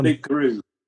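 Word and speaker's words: Loup-Garou: But then 2 years ago, you start Loop Guru. Is that how you pronounce the Loop Guru. Yes, Loop Guru, which is French for werewolves.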